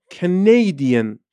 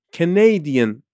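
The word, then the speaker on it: Canadian: یه آدم کانادایی چی میشه کانیدین کانیدین استرس روی سیلاب دومه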